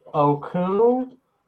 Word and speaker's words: Okung: Russell Okung Oh, I don't know how to pronounce it. – No, you're probably right.